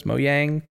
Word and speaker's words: Mojang: Mojang? I'm not sure Mojang. How you pronounce it. You do pronounce it. It's not like jalapeno.